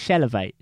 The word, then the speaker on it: Chilavert: How'd you say his name? – Chilavert. – What did I say? Chevrolet. – Chevrolet, yeah. – What is Chilavert? – Chilavert.